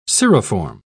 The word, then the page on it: cirriform: British English